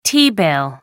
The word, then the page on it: t-bill: American English